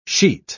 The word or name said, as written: sheet